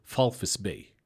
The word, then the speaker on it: Walvis Bay: But the V is an F. So it's Falfis Bay is how you say it, is how it's supposed to be said, you know.